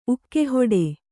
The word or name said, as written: ಉಕ್ಕೆಹೊಡೆ